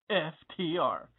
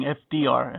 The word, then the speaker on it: FTR: You're listening to The Right Hook on the Mighty FTR. And yeah, I had to make sure I pronounced the T because it sounds like I'm saying FDR.